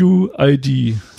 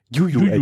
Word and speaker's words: UUID: UUID. UUID. Also es wird tatsächlich ähnlich wie das Jojo ausgesprochen, nur mit U. – UUID.